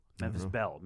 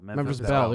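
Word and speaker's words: Memphis Belle: Like Memphis Blue type of shit. You know, like did I say that right? Memphis Bell. – Memphis Bell. There you go.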